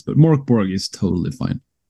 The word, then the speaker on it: Mörk Borg: It's you can pronounce it in a lot of different ways. Right. So we've learned the last couple of years. But in Swedish, I think it's intended to sound Mörk Borg.